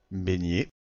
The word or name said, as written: baigner